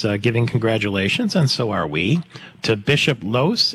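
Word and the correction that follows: Lohse: Is that how you say his name? What happened with him? – Losey. Bishop Edward Losey.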